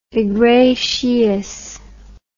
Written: iglesias

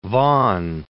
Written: Vaughan

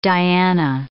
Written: Diana

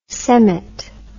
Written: semmit

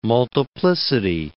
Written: multiplicity

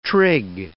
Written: trig

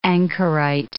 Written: anchorite

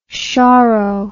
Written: choro

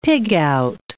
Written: pig-out

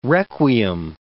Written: requiem

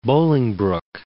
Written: Bolingbrook

